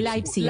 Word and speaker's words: Leipzig: Ahora, ¿cómo se dice en alemán, perdón? Leipzig, se dice Leipzig.